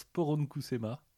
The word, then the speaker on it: poronkusema: Euh, un mot finnois qui, se prononce poronkusema, uh-huh.